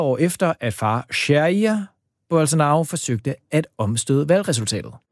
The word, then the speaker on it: Jair: Altså, nu har vi fået besøg af dig, Christian Anblad, DR's Sydamerika-korrespondent, og jeg ved slet ikke, om jeg udtalte Jair Bolsonaros navn rigtigt, eller oversatte helt korrekt, hvad det var, Flavio her fik sagt i sin jiu-jitsu-dragt. – Jo, det tror jeg. Altså, Jair. Altså, Jair.